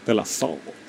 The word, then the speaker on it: De La Soul: Es en inglés. Se pronuncia en inglés. De La Soul. De La Soul. – De La Soul. – Meten una W ahí en medio de la.